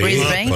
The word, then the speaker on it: Brisbane: Nos escuchan en La Boca, en Parque Patricios, en Almagro, manejando en Ruta 2, regresando, por ejemplo, de Miramar, en Brisbane, Australia. ¿Lo pronuncié bien? – Sí, Brisbane. – Por supuesto.